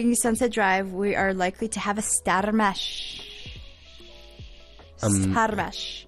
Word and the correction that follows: stramash: Oh, no, I'm saying it wrong. It's a stramash.